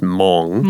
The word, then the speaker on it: Hmong: Teacher Garrett told me the H is silent on this word. So it is just Hmong.